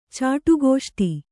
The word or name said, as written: ಚಾಟುಗೋಷ್ಟಿ